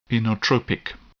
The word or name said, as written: inotropic